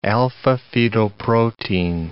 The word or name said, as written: alpha-fetoprotein